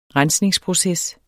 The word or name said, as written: rensningsproces